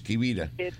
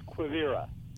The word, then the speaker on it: Quivira: I pronounce it in Spanish, Quivira. – Well, you're probably closer to correct, but the way the locals say it is Quivira.